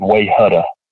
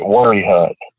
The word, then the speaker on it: Wayehutta: But at the same time, you know, there are things like there's a place here that it's spelled W-A-Y-E-H-U-T-T-A, which to anybody else would look like way hutta. But we pronounce it worry hut.